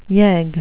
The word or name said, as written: yegg